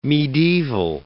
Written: mediaeval

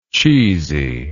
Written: cheesiness

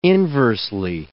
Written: inversely

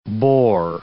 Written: bore

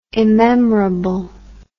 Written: immemorable

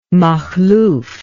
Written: Makhlouf

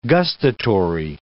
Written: gustatory

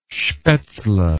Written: spaetzle

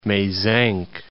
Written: Mezenc, Mount

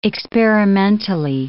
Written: experimentally

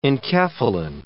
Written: enkephalin

